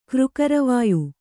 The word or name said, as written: ಕ್ರುಕರವಾಯು